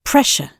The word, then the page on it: pressure: British English